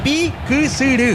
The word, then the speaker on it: Piquisirí: Es Piquisirí. – Sí, así señor. Se pronuncia. Se pronuncia bien, así como dijiste.